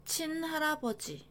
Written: 친할아버지

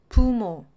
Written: 부모

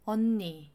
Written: 언니

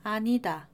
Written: 아니다

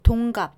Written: 동갑

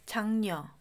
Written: 장녀

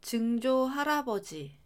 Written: 증조할아버지

